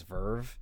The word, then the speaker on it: VRV: Also, I didn't realize that you're supposed to pronounce VRV as Verve.